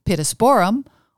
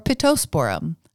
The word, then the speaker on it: Pittosporum: Pittosporum, for example, can be pronounced pittosporum or pittosporum, a half-rhyme with petroleum.